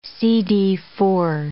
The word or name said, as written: CD4